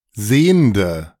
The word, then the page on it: Sehnde: ドイツ語; [ˈzeːndə]